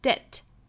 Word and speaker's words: debt: 首先呢，incur 这个词它的重音在第二个音节，所以读起来应该是的的 incurs 后面的这个 s 读浊辅音的 z incurs，再跟 a 连读 z incurs 后面这个 debt 这个词呢，里头这个 b 是不发音的。所以这个词音标就是 debt incurs a debt to the truth.